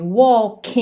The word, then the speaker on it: walk: Lembrando sempre que walk é uma palavra que engana um pouquinho, porque esse L não vai ser pronunciado como L mesmo. A gente não leva a língua lá no céu da boca. Walk. E sim, walk. Walk.